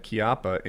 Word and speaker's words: Chiappa: Is that how you pronounce Chiappa? – Yeah. I took Italian. – Yeah. Okay, then. Chiappa.